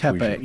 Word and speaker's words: Tepe: Which Tepe. You're right. Pe, not P. – Yeah. – Göbekli Tepe. – Tepe. – Tepe. P-E-H. Tepe. – Tepe. Okay. – Yeah. Göbekli Tepe.